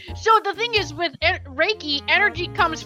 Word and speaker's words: Reiki: So Reiki, pronounced Reiki, R-A-Y-K-E-Y, that's how it's pronounced, is a Japanese technique for stress reduction and relaxation that also promotes healing.